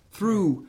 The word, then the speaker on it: through: Als je bijvoorbeeld het woord through hebt, through, daar zit een GH op het eind. Dan spreek je niet uit.